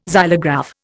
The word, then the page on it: xylograph: Indian